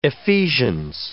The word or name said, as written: Ephesians